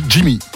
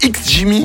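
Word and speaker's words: XGIMI: Oui, c'est Jimmy. – C'est X Jimmy? – Ouais, mais je crois qu'on dit que Jimmy, en fait.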